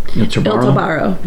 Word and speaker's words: Il Tabarro: How do you pronounce it? – Il Tabarro.